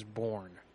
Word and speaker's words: Bourne: Oh, well, my name is Bourne. B-O-U-R-N-E.